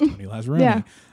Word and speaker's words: Tony Lazzaroni: For mine, it was always my last name rhymes with my first name. It's Tony Lazzaroni.